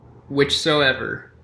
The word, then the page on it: whichsoever: American English